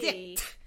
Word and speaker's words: Dict: I have to pronounce the T. – Yeah, Dict. – You have to pop that T. – Dict. Like dictionary jokes.